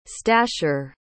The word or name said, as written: stasher